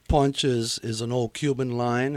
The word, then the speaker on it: Punch: And I don't know if you pronounce it punch or punch. – Punch. – Is it punch? – Yes, sir.